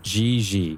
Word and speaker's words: JJ: Try it with a softer G. – Gigi. – Yeah, like there you go. Gigi. – Gigi. – It's the pronunciation of the letter J in the French language.